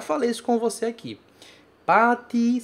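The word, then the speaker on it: pati (パーティー): Eu não falo ti, por exemplo, no meu dia a dia. Mas aqui eu tenho que pronunciar pati.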